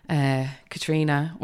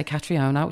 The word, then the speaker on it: Katriona: And so she, the first day she was like, Katriona, welcome, little Irish girl. And she said my name correctly because the Brits can't say it correctly. They all say Katriona, which is really fucking annoying.